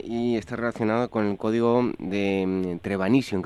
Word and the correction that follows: Trevanion: creo que lo digo bien. – De Trevanio.